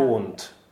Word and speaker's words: Mond: Die aspirierten Konsonanten kommen im Deutschen viel häufiger vor als in jeder anderen Sprache, und D wird eben oft wie ein T ausgesprochen, yeah. Mond.